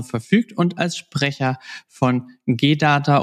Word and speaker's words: G DATA: Also äh, du lagst mit der ersten Aussprache G-Data vollkommen richtig.